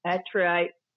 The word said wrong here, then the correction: anthracite: grey. – Yeah, anthracite, is it? Anthracite.